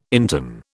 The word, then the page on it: interne: British English